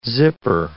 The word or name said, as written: zipper